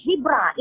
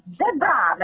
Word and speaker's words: zebra: Baru-baru ini orang Afrika pun cakap, you don't teach us how to pronounce our animal. It's not zebra, it's zebra.